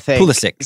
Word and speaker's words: Pulisic: Is it the hard C or not? – Pulisic, hard C. – Pulisic said that he didn't like didn't use the Czech pronunciation of the name.